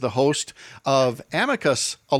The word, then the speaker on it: amicus: I think the accepted is amicus with the emphasis on the first syllable, as we often say.